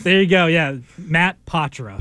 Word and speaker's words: Poitras: I was trying to do Poitra. – Yeah, Poitra. – Me too. Here it is. – Patra. Right. – Don't look at the way his name is spelt. It's P-O-I-T-R-A-S.